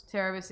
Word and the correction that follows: Cerberus: Is it Cerebus? Am I saying that right? – Cerberus. – Cerberus. That's I'm right.